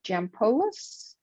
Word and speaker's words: Jampolis: This is your host and my special guest today is Dr. Melina Jampolis.